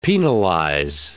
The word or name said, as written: penalize